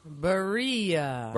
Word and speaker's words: Barilla: Is it Barilla? – Barilla.